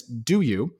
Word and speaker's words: Douyu: There's a company called Douyu, which we're pronouncing as Do You, which priced this week.